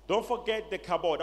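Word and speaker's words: kabod (כבוד): I want you to take your hand and touch your head and say kabod. – Touchez la tête et dites kabod. – Now it's K A. It may pronounce ka, but it's ka. It's Hebrew. Kabod.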